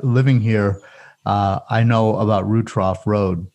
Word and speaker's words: Rutrough: But for people who are looking at it on a map, it looks like it's like a lot of people want to pronounce it Rutrow. – Exactly. – R-U-T-R-O-U-G-H.